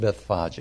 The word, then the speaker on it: Bethphage: And notice it's pronounced Bethphage.